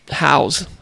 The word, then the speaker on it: Haws: H-A-W-S. How would you say that? – Hows. – Haws. – Haws. – Yeah. – Yeah, there we go.